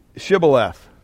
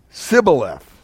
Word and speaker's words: Shibboleth: Verse 6, they said to him, then say Shibboleth. And he said, Sibboleth. So apparently there was a dialect here, an accent, whatever. They couldn't pronounce the SHs.